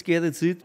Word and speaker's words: z'Geretsried: Das ist der Egeländer Chor Zgeretz Ried. Habe ich das richtig gesagt? – Richtig, also es ist der Chor der Echenander Gmoiz Zgeretz Ried.